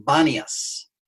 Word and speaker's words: Banias: The Arabic speakers can't pronounce P, so they pronounce B, so it became Banias.